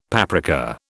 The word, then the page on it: paprika: British English